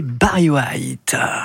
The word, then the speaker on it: Barry White: C'était Barry White. J'ai bien dit. – Very good pronunciation.